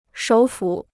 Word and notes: 首府: shǒu fǔ

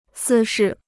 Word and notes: 私事: sī shì

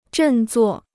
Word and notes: 振作: zhèn zuò

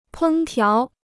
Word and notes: 烹调: pēng tiáo

